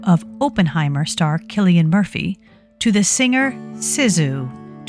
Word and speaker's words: Cillian: And the first name of the Irish actor Cillian Murphy is pronounced Cillian.